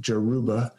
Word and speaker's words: Yoruba: This is a god, a very important god of the Yoruba nation, the Yoruba, or sometimes I've heard it pronounced like Jaruba.